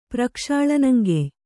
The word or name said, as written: ಪ್ರಕ್ಷಾಳನಂಗೆಯ್